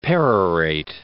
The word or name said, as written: perorate